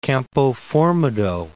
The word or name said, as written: Campoformido